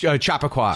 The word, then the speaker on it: Chappaqua: Hillary Clinton being spotted in the, I'm going to butcher the place in upstate New York, Chappaqua. What is it? – Chappaqua.